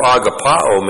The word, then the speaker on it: agapao: Jesus uses the verb agapao. A-G-A-P-A, long O. Agapao.